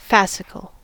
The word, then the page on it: fascicle: American English